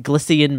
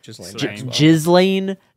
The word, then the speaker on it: Ghislaine: Glycian Maxwell? How do you say her name? – Ghislaine.